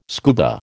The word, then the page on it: scuba: American English